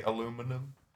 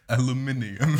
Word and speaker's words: aluminium: Do you say aluminum? – Don't make me say that. – All right. Never mind. – Aluminium. – There you go. – That's the British way of saying it.